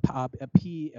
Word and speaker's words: PAOK: Um, and then, I mean, if you look at the, you know, they play Cardiff they cl- play uh, PAOK.